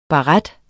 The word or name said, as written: baret